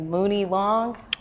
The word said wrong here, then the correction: Money Long: Oh, Money Long.